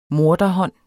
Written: morder

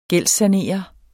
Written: gældssanere

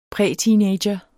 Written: præteenager

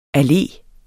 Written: alle

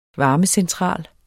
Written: varmecentral

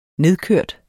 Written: nedkørt

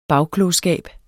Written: bagklogskab

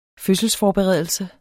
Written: fødselsforberedelse

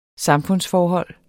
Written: samfundsforhold

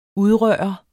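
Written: Udrørt

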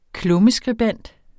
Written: klummeskribent

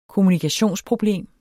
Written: kommunikationsproblem

